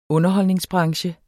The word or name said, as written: underholdningsbranche